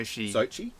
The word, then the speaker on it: Sochi: He lived in Sochi. I don't know how to say it properly. – Sochi.